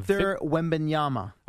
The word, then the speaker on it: Victor Wembanyama: Victor Wembanyama.